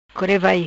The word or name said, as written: Core Vai